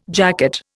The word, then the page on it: jacket: Indian